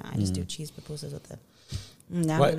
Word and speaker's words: pupusas: And I love pupusas. Am I saying that right? – Pupusas, yeah.